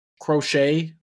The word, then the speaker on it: Croce: Oh, Croak, I thought you were going to say Jim. – Croce. Jim Croak. Croce. Croce, maybe that sounds right? – Croce. Probably Croce, actually. If O-E, right? R-O-E. – C-R-O-C-E.